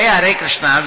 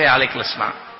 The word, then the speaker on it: Hare Krishna: But if we can say Hare Krishna, we shouldn't say Ale Krishna.